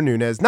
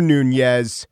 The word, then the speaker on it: Nunes: Rosenstein, Mueller, Nunes. Not Rosenstein, Mueller, or Nunez.